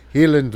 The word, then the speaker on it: Highland: They say Highland Laddie here, but the Scottish pronunciation is Highland. Healing Laddie.